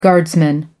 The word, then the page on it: guardsman: American English